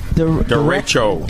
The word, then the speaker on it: derecho: Derecho. – Derecho. – Derecho. It's like a S-H. It's like a S-H. Okay, derecho. – Derecho. – D-E-R-E-C-H-O.